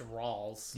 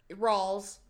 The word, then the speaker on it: Rawls: No, I think you spelled it right, but I think it's just pronounced Rawls. – You spelled it. – Okay, yeah. Well, Rawls, my bad.